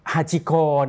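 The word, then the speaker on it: ฮา ชิโก (ハチ公): โอะนะครับแปลว่าเดินเล่นนะครับแปลว่าเดินเล่นนะครับอันนี้เป็นคำโปรยข่าวของข่าวนี้นะครับที่เราจะดูด้วยกันในสัปดาห์นี้นะครับฮาชิโกนะครับเวลาคนญี่ปุ่นออกเสียงก็จะออกเสียงยาวฮาชิโกนะครับแต่ว่าพอมาพูดเป็นภาษาไทยนะครับคนไทยเราก็จะเรียกฮาชิโกฮาชิโกฮาชิโกอย่างนะครับก็เป็นสุนัขที่มีชื่อเสียงมากนะครับชื่อเสียงในเรื่องของความกตัญญูนะครับฮาชิโก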